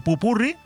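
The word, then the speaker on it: popurrí: y hacemos un buen pupurrí, con acento en la I, para que el fin de semana, sábado y domingo, también los disfrutes.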